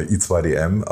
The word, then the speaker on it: I2DM: Von der, wie wird das eigentlich ausgesprochen? 1-2-DM GmbH oder 1-2? – Das war ja nur ein Spaß, der Name. I2DM, aber das...